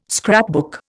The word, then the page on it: scrapbook: Indian